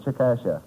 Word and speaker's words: Chickasha: C-H-I-C-K-A-S-H-A. I said Chickasha. – Oh, Mr. McNeil. – That is not right, I've taken. No. But the name is pronounced Chickasha.